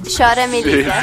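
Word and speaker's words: Chora, Me Liga: Cora Meliga, ¿sí lo dije bien? – Chora Meliga. – Sí, sí. – Chora Meliga, o algo así.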